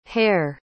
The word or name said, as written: haire